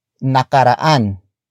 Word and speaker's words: nakaraan: It is the focus marker that introduces the main noun of the sentence, and that is nakaraan.